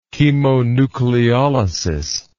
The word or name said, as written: chemonucleolysis